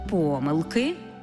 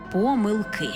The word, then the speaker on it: помилки: І до того ж маємо варіантне наголошування цього слова. Бо якщо йдеться про 2, 3, 4, тоді помилки. Якщо ж більше чотирьох, наголос падає на останній склад – помилки.